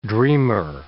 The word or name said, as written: dreamer